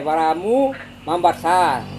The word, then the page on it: varamu mambasar: Serewen